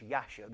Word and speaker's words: Jasher: the book of Jasher, or which is rightly pronounced Yasher because there is no J in Hebrews.